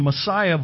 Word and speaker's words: Masaya: This is it's pronounced the Masaya Volcanic Park in Nicaragua.